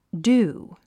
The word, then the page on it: dew: d j úː; デュー